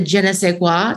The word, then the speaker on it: je ne sais quoi: The je ne sais quoi.